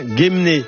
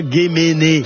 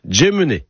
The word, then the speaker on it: Guémené: Et on dit bien Guémené, et pas Guéméné. Non, non, Guémené. – Nom d'un petit breton. – Et tiens, on va parler de Guémené dans le Morbihan. Alors, en breton, on prononce Djemené.